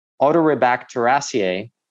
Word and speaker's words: Enterobacteriaceae: And they also had strains of a type of bacteria called Enterobacteriaceae, which, don't you love these names? – You pronounced it well.